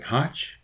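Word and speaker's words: Hoch: Uh, Mr. Mike Campbell, uh, Mr. Ju- uh, Judith Hotch, H O C H, I think. Is that how you say it? Hotch?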